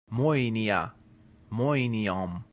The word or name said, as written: moenia